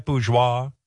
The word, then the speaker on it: bourgeois: It can come off very bourgeois. Am I saying that right, bourgeois? – Bourgeois. Bourgeois. Yes, very close, Howard. Very good. Bourgeois. You really have to let the phlegm in there.